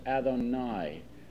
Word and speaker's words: Adonai: It's a word that's pronounced Adonai.